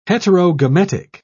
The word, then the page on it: heterogametic: British English